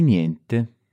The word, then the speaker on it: niente: Notice the pronunciation here of niente. In some ways, it's similar to the ending of grazie. – Grazie. – Which we talked about in the last episode. We've got this ye sound at the end, but this time it has an N before it.